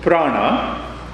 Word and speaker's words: prana: So when we talk of prana, that is one. Let me give you some, out of the... So when we talk of prana, pra-na, two syllables.